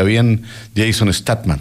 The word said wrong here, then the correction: Statham: ¿Está bien dicho o no? O puede Statham, ser. Algo así. – Statham. – Ah. Statham. Así que los invito a que vean esas películas.